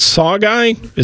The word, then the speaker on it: sauger: Is it Sawguy? Is that how you pronounce that? – Yes, you got it.